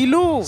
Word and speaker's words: Siloh: il faut prononcer comme tu l'as bien dit. Silo.